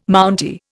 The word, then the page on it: Mountie: Indian